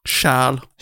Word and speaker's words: Charles: How do you say Charles en français? – Charles. – Charles.